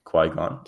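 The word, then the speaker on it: Qui-Gon: I was watching the first one too, uh, Keegon. I always say his name wrong, but Keegon Jin. – Qui Gon. Yeah. – Sorry, I preface this by saying I always say it wrong.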